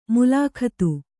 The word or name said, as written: ಮುಲಾಖತ್ತು